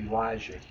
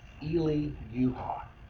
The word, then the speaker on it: Elijah: Interesting little side note, I'll just make this comment, but the word Elijah, you know, we pronounce it Elijah here. In Hebrew, they pronounce it Eli-Yuha.